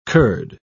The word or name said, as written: curd